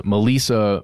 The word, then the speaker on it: Maleesa: But that's a key thing here, because the second person who was found, Melissa Mooney, that's how I'm choosing to pronounce it, M-A-L-E-E-S-A.